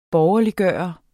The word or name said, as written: borgerliggøre